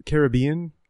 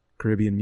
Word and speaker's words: Caribbean: Uh, by the way, uh, so Carib- Caribbean comes from the native indigenous tribe called the Caribs, and they pronounce oh. it Carib. – And so, because the pronounce the, the emphasis on the first syllable, it ends up when you use the whole word, it's car- the whole word, it's Caribbean.